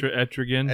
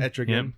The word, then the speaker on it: Etrigan: and Etrigan the Demon. Did I say that right? – Etrigan. – Etrigan.